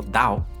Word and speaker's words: Tao: En China tenemos el Tao, que se pronuncia, si no me equivoco, Dao.